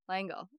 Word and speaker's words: L'Engle: I think Madeleine it's Langle. – Langle. Okay. There we go.